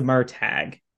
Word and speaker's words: Murtagh: Also, I looked in the pronunciation guide. It's the Murtagh.